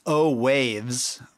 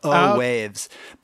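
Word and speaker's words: Owaves: And I already messed up because I said the name wrong. It's O-Waves, like a wave that a surfer rides. So O-Waves. But I'm hoping that just by doing that,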